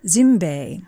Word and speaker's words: Zimbe: Is it pronounced Zimbe? – Zimbe. – Okay.